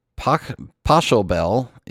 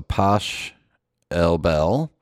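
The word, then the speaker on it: Pachelbel: The canon is pronounced like cannon, so cannon, and poshelbel is as in chutzpah, so poshelbel.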